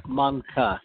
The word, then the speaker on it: Menke: It's Monka. Kind of rhymes Monka. – With Tonka, like a Tonka truck, or Wonka, like Willy Wonka and the Chocolate Factory.